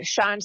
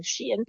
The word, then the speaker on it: Shein: Which, if you think about it, makes more sense as a name of an Shine sounds a lot better than Shein.